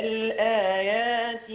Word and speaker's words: الآيات: الياء في الآيات، أنا عايزك كتير بيقع في الخطأ في نطق الياء، بيعومها كده مش بيحقق مخرجها، يقول قد فصلنا الآيات، قد فصلنا الآيات، فين الياء؟ قد فصلنا الآية يا مش الآيات لآيات، لا الآيات، خدت بالك؟ – تمام يلا.